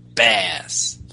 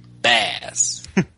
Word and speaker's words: Baz: is named Bass. Bass. – Now, Bass, not to be confused with like current Mega Man bad guy, but is spelled B-A-Z, is translate roughly into Falcon, if I'm correct.